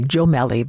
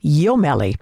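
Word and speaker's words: Jommelli: His last name is spelled with a J, so it looks like Jomelli, but it's actually pronounced Jomelli.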